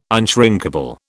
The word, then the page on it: unshrinkable: British English